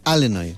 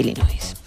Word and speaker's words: Illinois: Y si uno busca ese nombre fonéticamente, es Illinois. Así que Illinois y la S. Así que bravo, sin pronunciar bravo. La S final, Illinois, una vez más, bravo, tu apunte de geografía que vale.